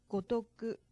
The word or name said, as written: gotoku